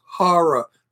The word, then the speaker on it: horror: The word is horror, not whore.